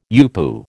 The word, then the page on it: UPU: American English